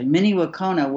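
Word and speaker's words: Mni Wiconi: You know, we talked in back in the day, Miniwakona, I never said it right initially, Miniwakona, which means water is life.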